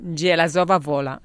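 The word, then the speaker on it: Żelazowa Wola: Frédéric Chopin, us recordaré que va néixer a Zelazowa Wola, no sé si ho pronunciat bé, suposo que no. – Zelazowa Wola. – Això mateix, Pola.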